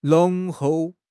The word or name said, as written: 浓厚